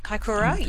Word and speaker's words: Kaikoura: Kaikoura. – Kaikoura, yes.